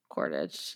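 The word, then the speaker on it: Quaritch: I can never say Quartich. – Yeah. – Quartich. I have a hard time saying that for some reason. – I don't even know how it's spelled. – It's Quartich. – Quartich.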